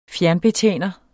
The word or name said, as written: fjernbetjener